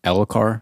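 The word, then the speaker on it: Elhokar: So we find out at the leather worker that the strap from Elokar, am I saying that right? – Yeah.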